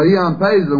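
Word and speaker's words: Ian Paisley: Ion Paisley,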